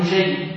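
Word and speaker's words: نُجَيّ: وعبد الله بن نجيب بضم النون وفتح الجيم وتشديد الياء تابعي معروف يروي عن علي رضي الله عنه.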